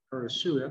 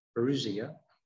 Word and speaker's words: parousia: So Jesus is coming, the parousia, I'm not saying it right today, parousia.